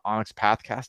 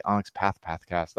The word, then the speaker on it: Onyx Pathcast: cause you guys are covering it in the Onyx Pathcast. I said it right. I didn't say Onyx Path Pathcast, the Onyx Pathcast.